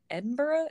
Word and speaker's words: Edinburgh: She was supposed to call me when she got to Edinburgh. Is that how you say it in Scotland? – Yeah. – So yeah, I think that seems right.